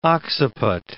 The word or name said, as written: occiput